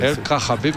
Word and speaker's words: Ελ Καχαμπή: Λέει καλησπέρα, μπορεί να πει ξανά πώς προφέρουν τον Ελ Καχαμπή οι Μαροκινοί. – Ελ Καχαμπή. – Ελ Καχαμπή. – Ελ Καχαμπή με το χο. Πολύ μεγάλο. Πολύ μέσα.